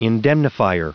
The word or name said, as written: indemnifier